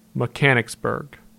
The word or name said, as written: Mechanicsburg